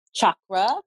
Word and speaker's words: chakra: It's, I believe the word chakra in itself, which I'm it's pronounced chakra. It's an ancient Sanskrit word.